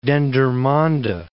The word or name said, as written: Dendermonde